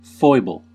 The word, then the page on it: слабость: /ˈslabəsʲtʲ/